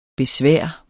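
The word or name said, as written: BESVÆRET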